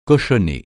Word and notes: cochonner: francais

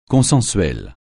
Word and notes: consensuel: francais

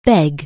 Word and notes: beg: anglais

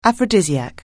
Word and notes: aphrodisiac: anglais

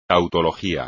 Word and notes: tautología: espagnol

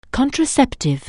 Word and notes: contraceptive: anglais

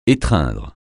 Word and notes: étreindre: français

